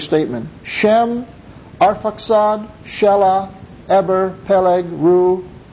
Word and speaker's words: Peleg: The name of one was Peleg, and that is the Hebrew pronunciation. That's how it's pronounced.